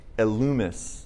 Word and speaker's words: Elymas: But, and this could be Elymas, or if you do the Greek pronunciation, which I prefer, it's Elumus.